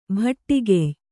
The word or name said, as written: ಭಟ್ಟಿಗೆಯ್